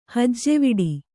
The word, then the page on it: ಹೆಜ್ಜವಿಡು: kannada